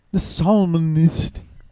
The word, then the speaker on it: psalmist: The psalmist. – Yeah. That's how you say it.